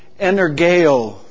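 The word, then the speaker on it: energeo (ἐνεργέω): It's the word in Greek that's pronounced like this, energeo.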